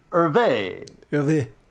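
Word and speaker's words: Hergé: Is that how you pronounce it? It's H-E... – E with an accent aigu. – Hervé. – With a G accent, at the end? – Accent grave on the final.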